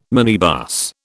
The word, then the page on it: minibus: British English